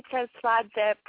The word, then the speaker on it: Tsertsvadze: my whole name is Sally Tsertsvadze Pramraj.